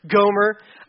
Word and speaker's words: Gomer: I'll turn my head and be like, all right, Homer. No, see, I just said it wrong. Gomer.